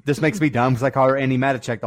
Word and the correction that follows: Matichak: the whole movie, uh, the whole review, but the interview on Collider was Andi Matichek. So whoops. I've been saying her name wrong, and I'm in.